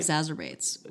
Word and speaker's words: exacerbates: That causes things, people to, it just exacerbates. Am I saying that right? – Exacerbates? Yeah, you said exacerbates like jazzercise. – Right. – Exacerbates, which is fun.